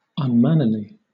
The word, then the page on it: unmannerly: British English